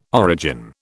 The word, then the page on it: origin: British English